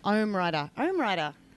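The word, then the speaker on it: OmmWriter: I can never say the name of it. OmiWire. Do you pronounce it two M's? – OmWriter.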